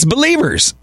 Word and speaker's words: BLVRS: It's Believers, but it's spelled with zero vowels. B-L-V-R-S. Yeah, the pro tip, you just leave out all the vowels.